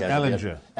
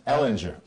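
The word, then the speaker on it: Ehlinger: Sam Ellinger. – Ellinger, right.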